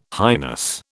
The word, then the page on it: highness: British English